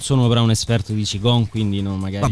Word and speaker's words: Qigong: Però in realtà c'è un'altra traccia interessante, che è questa del, adesso la pronuncia, di questa disciplina orientale, diciamo così, come si dice, Qigong. – Qigong in italiano. – Ok, Qigong.